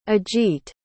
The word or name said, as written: ajit